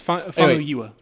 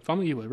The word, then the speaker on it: Famuyiwa: I just said it wrong. It's Famuyiwa. Famuyiwa. Rick Famuyiwa.